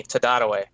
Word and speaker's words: Tararuj: Tadadaway. That's how you pronounce Casey's last name. You'd never guess. – Tadadaway? – Tadadaway. – I had no idea. – Yep. – But no, what's crazy is she spells that T-A-R-A-R-U-J.